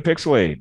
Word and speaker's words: PixelHate: Welcome to PixelHate, where the H is silent, but the hate is very, very real.